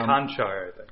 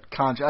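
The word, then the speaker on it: Kanter: Might have helped them more than uh, Kantar. I don't know how to say his name, but um, Conchar. – Conchar. I think you're right. It is Conchar.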